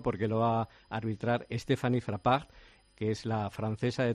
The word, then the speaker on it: Frappart: Stéphanie Frappart.